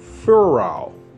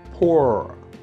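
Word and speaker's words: Purah: Verse 10, it says, but if thou fear to go down, go thou with Phurah, or better pronunciation, Pura.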